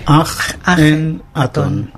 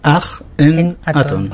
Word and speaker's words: Akenatón: ¿Cómo se dice? – Aj-en-atón. – ¿Aj-ge? – Aj-en-atón.